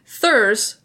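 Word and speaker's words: þurs: And the Norwegian word tus evolves from the Old Norse rune used to describe the Jotnar, which is where thurs, which is spelled with like a thorn, and then U-R-S in Old Norse.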